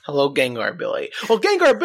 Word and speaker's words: Gengar: Yeah, that's how it's pronounced, Gengar.